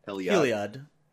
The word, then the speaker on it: Heliod: Heloid. How do I say this? Heloid? – Heliod. – Heliod, okay.